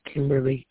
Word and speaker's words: Kymberly: Yes, my name is Kimberly Kelly. It's spelled K-Y-M-B-E-R-L-Y.